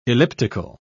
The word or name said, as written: elliptical